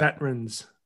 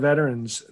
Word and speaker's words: veterans: Yeah, you see, this is where you're disrespecting our veterans. There's an extra syllable in there, and you're trying to shorten the word.